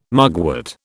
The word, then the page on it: mugwort: British English